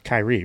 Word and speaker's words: Kyrie: It's Kyrie, right? – Yeah.